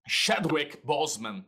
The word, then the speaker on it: Chadwick Boseman: como é que fala? Jack Ray Boseman, tem que falar rápido para parecer que falou certo. É, Chadwick que... Boseman. Chadwick Boseman.